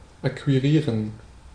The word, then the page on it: acquire: American English; /əˈkwaɪɚ/